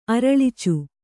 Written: ಅರಳಿಚು

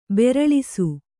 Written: ಬೆರಳಿಸು

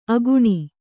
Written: ಅಕ್ಕಣಿ

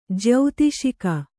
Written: ಜ್ಯೌತಿಷಿಕ

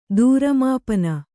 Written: ದೂರಮಾಪನ

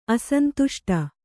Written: ಅಸಂತುಷ್ಟಿ

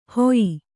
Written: ಹೊಯ್ಯಿ

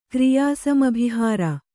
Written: ಕ್ರಿಯಾಸಮಭಿಹಾರ

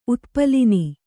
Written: ಉತ್ಪಲಿನಿ

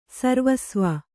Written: ಸರ್ವಸ್ವ

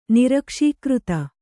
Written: ನಿರಕ್ಷೀಕೃತ

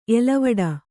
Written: ಎಲೆವಡೆ

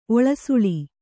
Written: ಒಳಸುಳಿ

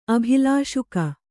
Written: ಅಭಿಲಾಷುಕ

